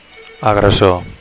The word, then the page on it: agressor: Catalan